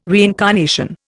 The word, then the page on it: reincarnation: Indian